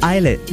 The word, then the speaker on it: ILLIT: Eyelet is spelled I-L-L-I-T. It's not illet, it's eyelet. – Like, don't we play an artist called Jake and his name is like J-V-K-E.